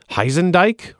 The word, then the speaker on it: Horsedich: The judge's name is spelled H-O-R-S-E-D-I-C-H, which the judge says is pronounced Heisendike or something like that, but naturally it gets pronounced horse.